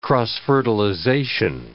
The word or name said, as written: cross-fertilization